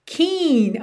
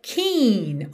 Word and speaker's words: Keane: E atenção aí, já começamos a aula agora porque a pronúncia da banda é Keane. Keen, ok? Embora a escrita seja K-E-A-N-E, naturalmente soa aí em português Keane pra gente quando a gente lê a palavra, mas a pronúncia é Keen, alright?